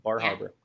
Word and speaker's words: Bar Harbor: Bar Harbor.